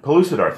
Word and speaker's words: Pellucidar: But Bekilder, I think it was called. – Oh, Pellucidar. – Pellucidar, thank you.